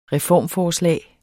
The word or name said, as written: reformforslag